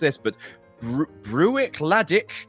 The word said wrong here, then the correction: Bruichladdich: Bruichladdich Classic Laddie this evening.